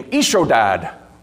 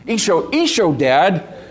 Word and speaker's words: Ishodad: The quote comes off of this fact that there was a writer named, I have a hard time pronouncing his name, Ishodad. Sounds like is your dad. Yeah. Ishodad,